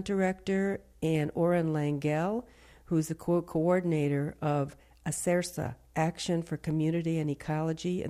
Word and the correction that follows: ACERCA: Did I pronounce that right? – Acerca. – Acerca.